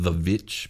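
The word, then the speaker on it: The VVitch: Yeah, it's The Witch. I some people might pronounce it The Witch because it's two V's, but it's really just pronounced The Witch.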